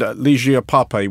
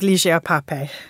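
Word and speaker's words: Lygia Pape: Finally, we should look to Hauser & Wirth and Ligia Pape, which we think is how it's pronounced. This is what we have been advised by our Brazilian producer. – Indeed. I must say, I've heard that name pronounced so many different ways, but we will go with Ligia Pape.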